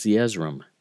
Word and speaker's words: Zeezrom: I looked it up to see how it's pronounced, whether it was Zeezrom or Zeezrom, and according to the Book of Mormon pronunciation guide, it is Zeezrom, so I'm going to run with that.